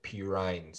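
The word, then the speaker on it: Perine: You had, I can't remember his name, but Panarin, number 34 on the Bengals. – I think you pronounce it Pirine. Samaj Pirine. Yeah, I think that's how you pronounce it.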